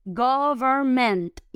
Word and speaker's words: government: Quiero que por favor la pronuncien con vibración. Gover, gover, gover. Y luego ment, government. Y que suene la T final.